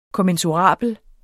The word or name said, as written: kommensurabel